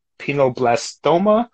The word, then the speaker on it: pineoblastoma: Now, the type of brain cancer that she had, it's called pineoblastoma? – Yes.